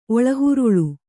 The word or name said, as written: ಒಳಹುರುಳು